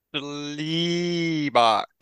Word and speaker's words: Schlebach: We got Christian Schlebach.